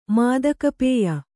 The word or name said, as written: ಮಾದಕಪೇಯ